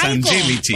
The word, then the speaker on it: Angelici: Angelici. – No, Claro. Pronunciación es Angelici.